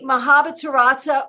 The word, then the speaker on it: Mahabharata: So we know that you're an artist and we know that you are the creator of the Mahabharata Oracle.